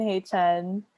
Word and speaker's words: Haechan: Haechan is the Korean way to say it. – Yeah. – Haechan.